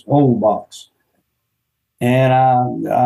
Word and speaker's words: Holbox: The name of the island's H O Holbox, but it's they don't pronounce the H in Spanish. It's Holbox and I,